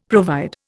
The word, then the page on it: provide: Indian